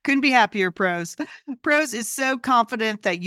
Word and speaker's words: Prose: And PROS is spelled P-R-O-S-E.